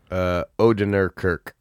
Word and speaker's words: Odenkirk: Odenkirk.